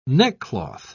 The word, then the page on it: neckcloth: British English